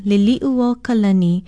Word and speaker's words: Liliʻuokalani: Aloha, I'm Lilani Poliahu of Hawaii Public Radio, and our Hawaiian word of the day is a well-known name, Liliʻuo Kalani. It is spelled Liliʻuokalani. She was our last queen, overthrown in January 1893. Many mispronounce her name because of familiarity with the English name Lily. Remember, it's Liliʻuo Kalani.